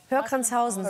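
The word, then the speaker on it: Höhr-Grenzhausen: Bei dir ist das Hörgrenzhausen und bei dir ist es Neukirchen-Flühen. – Ja. Hörgrenzhausen? – Ja. – Ich habe es noch nie richtig ausgesprochen. Ja. – Hörgrenzhausen.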